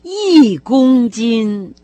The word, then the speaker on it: 一公斤: Если вы внимательно слушали, как произносит словосочетание «один килограмм», то наверняка заметили, что числительное «один», которое вообще-то должно иметь первый тон, она почему-то произнесла с четвертым. – «И-гун-дин».